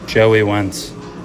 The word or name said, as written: Joey Wentz